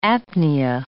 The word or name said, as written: apnea